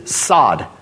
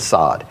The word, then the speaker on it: sod: And that Hebrew word secret counsel is sod. That's how you pronounce it, like what you grow grass with, sod.